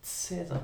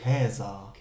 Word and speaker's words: Cäsar: Cäsar, TZ. – Falsch. – Wie? – Cäsar. – Wirklich? – Ja. Das ist die lateinische Aussprache. Da kommt auch der deutsche Kaiser her.